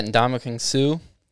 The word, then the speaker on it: Ndamukong Suh: Yeah, we've got Ndamukong Suh.